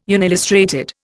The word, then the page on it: unillustrated: Indian